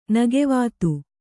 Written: ನಗೆವಾತು

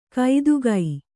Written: ಕೈದುಗೈ

